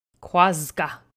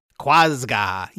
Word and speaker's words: Quazgaa: It is spelled Q-U-A-Z-G-A-A. – Quasga. – Quasga.